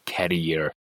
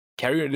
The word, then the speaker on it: career: we say career career that is not career Carrier is something else that it has to do with the babies, and they're gonna carry the babies with that thing.